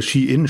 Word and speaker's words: Shein: oder Shein spricht man es ja jetzt wohl aus,